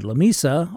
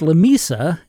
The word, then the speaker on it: Lamesa: An attempt to establish a 45-mile line between Big Spring and Lamesa also failed. And yes, it's pronounced Lamesa and not Lamesa, according to my wife, who's from Muleshoe, Texas.